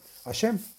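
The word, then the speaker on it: השם: del santo nombre que se escribe Hei Shin Mem y se pronuncia Hashem.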